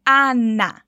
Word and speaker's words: Ana: Parece bem comum falar esse nome, mas repare na pronúncia dele. Ana. É como se todos os as dessa palavra tivessem um acentinho e ficassem bem abertos, como na palavra apagar, fugindo desse som nasal que a gente faz no português quando a gente diz Ana.